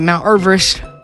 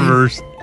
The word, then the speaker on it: Everest: I just want to let you guys know, you've been pronouncing Mount Everest wrong your whole entire life. – Okay. Was it Mount Everest? – No, it's something not Mount Everest. The correct pronunciation is Everest. – Still dumb. Everest. – It's named after Sir George Everest, a surveyor from Wales, and that's how his last name was pronounced.